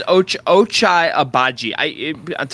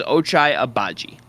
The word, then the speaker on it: Ochai Agbaji: I believe it's Ochai Agbaji. Tomorrow's show is all about the draft. We talked about him, and I had a phonetic... Well, I went down a YouTube rabbit hole of trying to like hear someone, as many people as possible, pronounce his name. And I believe it is Ochai Agbaji.